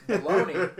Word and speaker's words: bologna: Bologna is, because the G is silent. Why else would the G be silent if it wasn't a superfood? – And the A sounds like an E.